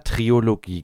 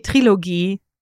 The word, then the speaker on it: Trilogie: ich habe immer Triologie gesagt. – Natürlich ist es die Trilogie.